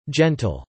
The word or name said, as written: gentle